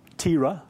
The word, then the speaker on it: Terah: So here we have the story here of Terah, which is what his name was. That's how it's pronounced.